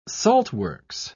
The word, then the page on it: saltworks: British English